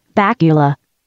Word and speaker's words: Bakula: It's pronounced Bakula, like Dracula.